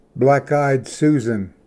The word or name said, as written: ᎠᏫᎠᎦᏔ